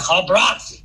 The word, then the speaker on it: Albracht: Yeah, Albracht. But we've kind of Americanized it to Albracht.